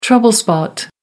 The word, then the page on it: trouble spot: American English